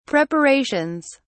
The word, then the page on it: preparations: British English